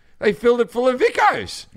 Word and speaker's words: Vikos: they were between Pepsi and Coke, but I have a cola brand that I drink when I'm in Greece, Vicos. It's spelled V-I-K-O-S. Vicos.